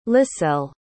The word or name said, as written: liselle